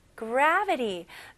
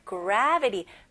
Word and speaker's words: gravity: Newton discovered gravity. 뉴턴이 중력을 발견했지. 여기서도 뭐를 발견한 거잖아요. 그래서 discover가 적합하죠. Newton discovered gravity. Gravity가 아니고 gravity.